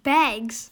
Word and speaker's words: bags: 发音的时候呢，加上 s，它的发音就会是 z，比如说 bags，就像一个 z 一样。